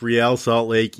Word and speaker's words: Real Salt Lake: I'm afraid to tell you that it's Real Salt Lake, even though that would be using the Spanish pronunciation of a word of a team that has nothing to do with Spanish or the Spanish language of any kind.